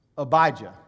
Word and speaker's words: Abijah: How do you say it again? Abijah. That looks just like that, doesn't it? That's how you say that.